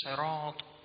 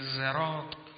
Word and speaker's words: صراط: این کلمه صراط رو اگر شما با سین هم تلفظ کنید ایرادی ندارد. حتی بعض از فقیهان قرائت با ز رو هم قبول میدونن، در کلمه زراط میشه نه صراط.